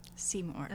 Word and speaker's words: Simurgh: Simurgh.